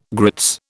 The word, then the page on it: grits: British English